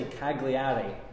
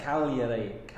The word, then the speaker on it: Cagliari: So we would all say Cagliari, but it's Cagliari. Cagliari or something. They silent the G.